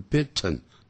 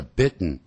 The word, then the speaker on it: bitten: 不要念成 bitten, 叫 bitten, 用鼻音这样出来啊。